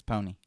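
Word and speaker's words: Poni: It's P-O-N-I. – P-P... Yeah, Pony. – So I think it's Pony.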